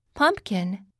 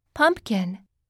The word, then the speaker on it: pumpkin: Pumpkin Pumpkin U を強くあと発音するよ。